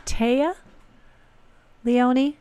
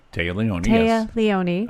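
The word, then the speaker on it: Téa Leoni: Actress, I believe she says it, Taya Leone. – Taya Leone.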